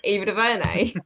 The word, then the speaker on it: Ava DuVernay: Did you just Digimon Honsu Ava DuVernay? – Oh, come on. There was like an extra syllable. Hang on. Yeah, okay, Ava DuVernay.